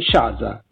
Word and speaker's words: Shaza: Oggi siamo in collegamento con la provincia di Como e siamo al telefono con Sofia Audino, in arte Shaza. Ciao. – Ciao. – Ciao, ho detto giusto il nome d'arte? – Certo, sì.